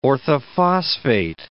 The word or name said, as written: orthophosphate